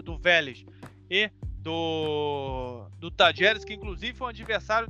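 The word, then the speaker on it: Talleres: Mas o Tajeres, como se fala na Argentina,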